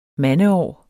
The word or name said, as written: mandeår